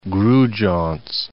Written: Grudziadz

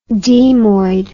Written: demoid

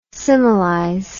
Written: similize

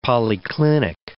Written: polyclinic

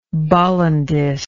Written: bollandist

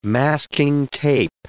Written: masking tape

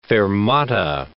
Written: fermata

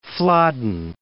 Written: Flodden

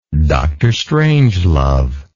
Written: drstrangelove

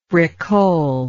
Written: bricole

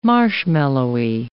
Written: marshmallowy

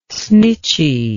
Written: snitchy

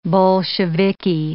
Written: Bolsheviki

